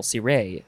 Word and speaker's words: ciré: You have to emphasize the second syllable, ciré, ciré. It is spelled C-I-R-E.